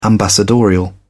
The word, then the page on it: ambassadorial: British English